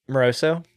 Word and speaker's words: Moroso: Tell me, okay, so from the moment you talked to Moroso, did I say that right? – Moroso? Yeah.